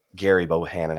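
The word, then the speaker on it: Gerry Bohanon: Jerry or Gary Bohanon, I guess is how it's pronounced, spelled Jerry, but um, Gary Bohanon had one interception coming into the game, and Texas intercepted him twice.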